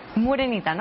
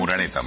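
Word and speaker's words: Moreneta: Morenita, ¿no? Así se pronuncia. – Muraneta, sí, bueno, casi.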